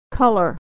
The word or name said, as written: color